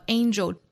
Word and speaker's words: angel: Ain 后面是 Joel, angel, Angel 有一个词呢是角度啊，它的拼写呢和 Angel 只差一点点，就是 A-N-G-L-E。那角度这个单词应该读成 Angle Angle，而天使这个词读成 Angel Angel，长得很像，只是字母 E 和 L 的颠倒，但是发音是非常不同的啊。